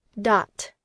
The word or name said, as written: dot